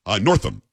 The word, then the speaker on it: Northam: But what the left has said, or what the right has said about Ralph Northup, Northup? Let me make sure that I have it right here. I don't want to mispronounce it because then I'll be Biden. Northam, N-O-R-T-H-A-M.